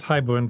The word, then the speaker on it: haibun: Am I pronouncing that correctly? That's correct. It's haibun poetry, yes.